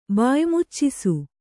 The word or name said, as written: ಬಾಯಿಮುಚ್ಚಿಸು